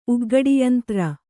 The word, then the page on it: ಉಗ್ಘಡಿಯಂತ್ರ: ugghaḍiyantra